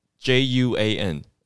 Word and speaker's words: Juan: 那 j u a n 那 j 在西班牙文的发音是 h，所以是其实是念起来像胡安啦。 Juan，所以 Don Juan，其实 Don Juan 的意思就是欢大人、胡安大人这样的概念。然后 J U A N 这是很常见的西班牙的名字，就很多人叫做 Juan，所以 Don Juan 其实是叫做 Juan 的一位老大的一个大哥。